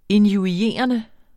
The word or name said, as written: injurierende